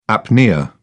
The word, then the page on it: apnoea: British English